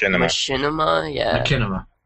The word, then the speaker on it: Machinima: Machinima.